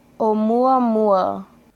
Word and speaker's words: Oumuamua: Oumuamua hey, ich war gar nicht so schlecht.